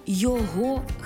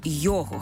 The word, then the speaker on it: його: Наприклад, до його хати, а не до його хати. До її дому, а не її дому. Розумієте?